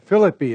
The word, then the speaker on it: Philippi: Philippi is the proper pronunciation, I understand, but I'm afraid I can't resist Americanizing it, so we'll do the best we can.